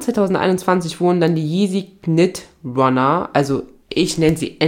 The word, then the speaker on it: knit: Der Name Knit kommt von dem Material gestrickt, also Knit, ich glaube, das K wird nicht ausgesprochen, also Knit, ist in dem Fall für Englisch für gestrickt, wenn das richtig übersetzt worden ist.